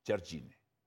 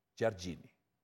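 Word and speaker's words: Jardine: La pronunciación correcta es Chargini, Chargini, Chargini.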